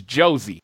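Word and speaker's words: José: Yeah, Jose Aldo. – Dude. No, and just to clarify, it's Jozy. I don't know why. I don't know why, but they pronounce the J in Brazil, so it's Jozy Aldo.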